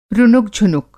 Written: रुनुक-झुनुक

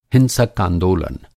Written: हिंसक

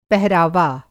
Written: पहरावा

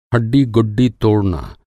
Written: हड्डी-गुड्डी

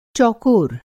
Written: चौकोर

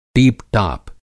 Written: टीपटाप

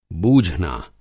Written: बूझना

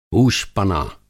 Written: हूशपना

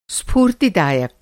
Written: स्फूर्तिदायक

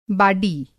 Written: बाडी

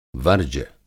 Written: वर्ज्य

